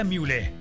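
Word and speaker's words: Mühle: Recuerda, se pronuncia Müle, M-U-H-L-E. Müle.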